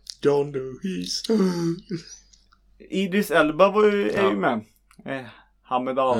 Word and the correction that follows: Heimdall: Mm. Och... – Heimdall. – Ja. Jag är så dålig på uttal.